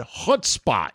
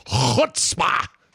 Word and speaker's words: chutzpah: chutzpah is or yeah, can't even say chutzpah. – You've pronounced that correctly. Most people can't do the part, but you've done it properly.